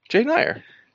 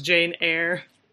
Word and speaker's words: Jane Eyre: Jane Eyre. – Yep. – Wait, what? Did you laugh at the way I said that? – I have known it to be pronounced Jane Eyre.